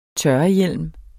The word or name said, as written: tørrehjelm